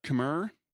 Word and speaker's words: Khmer: I'm probably going to pronounce this wrong, but Khmer? – Yep, the Cambodian one. I just found K-H-M-E-R, Cambodian, has how many, Kurt? – 74 letters in the alphabet.